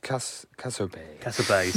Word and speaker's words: Cassiopei: I think it's Cassope. The Cassope C64 adapter. – Yeah. Cassope.